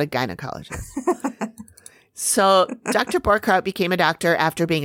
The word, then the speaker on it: Borchard: So, Borchardt, you know, and it's spelled Borchard. – Oh. – B-O-R-C-H-A-R-D, but he pronounces it Borchardt.